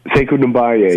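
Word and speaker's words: Sekou Doumbouya: Sekou Dembaya, yeah.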